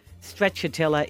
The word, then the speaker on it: Stracciatella: Stracciatella is the right pronunciation.